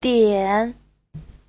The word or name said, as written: dian3